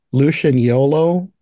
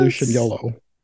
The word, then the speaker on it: Lucignolo: It, it's spelled L U C I G N O L O, but yeah, I think they were saying sounds... Lucian Yolo. – Lucian Yolo, that sounds kind of right.